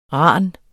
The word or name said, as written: ran